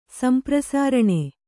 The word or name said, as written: ಸಂಪ್ರಸಾರಣೆ